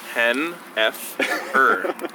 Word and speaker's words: Henefer: H-E-N-E-F-E-R. Hennifer, oh, right? Hennifer. – What the fuck does that mean? – Hen-F-er.